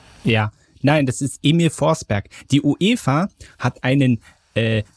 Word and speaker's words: Forsberg: Und da hieß Forsberg ohne Witz Forsberry ausgesprochen. – Barry. Total. – Ja, Barry, wie Beere, Barry.